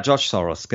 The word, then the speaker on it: George Soros: Habe ich den richtig 14. ausgesprochen? – Ja, George Soros, genau.